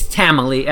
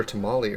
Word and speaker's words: Tamili: It's Tamily. I mean, I'm Tamily. Pronouncing it Tamily, but you're probably, it's like Emily with a ta instead of an e.